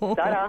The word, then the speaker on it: Sada: I was severely corrected when I said Sarah. It's Sada. Oh. – Wow. – Don't mispronounce the Portuguese name, trust me.